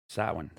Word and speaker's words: Samhain: Samhain. – That's hot. Well done, Samhain.